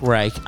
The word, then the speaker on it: Reich: Frank Reich. Uh, is it Reich? It sounds like I'm well, pronouncing the like the, third Reich.